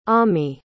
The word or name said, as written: ammi